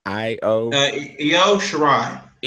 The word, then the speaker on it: Io Shirai: I mean, if I'm saying her name right, can you pronounce it again? – Which one? – The Io Shirai. – Io Shirai. Okay.